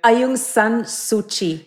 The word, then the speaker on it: Aung San Suu Kyi: So how do you say the name of the Myanmar woman again? – Aung San Suu Kyi.